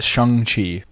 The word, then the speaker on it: Shang-Chi: Yeah, it's Shang-Chi. – All right, and that's as confirmed by Kevin Feige. – Yep, it's spelt Shang. Apparently it's pronounced Shang.